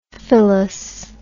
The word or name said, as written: philous